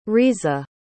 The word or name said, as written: risa